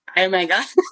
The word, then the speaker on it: Iomega: Is it iOmega? Is that how you pronounce it? – Yeah. – iOmega.